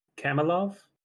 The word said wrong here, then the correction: Kornilov: Did I say that right? – Is it Kornilov? – Kornilov. That's right.